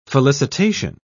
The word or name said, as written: felicitation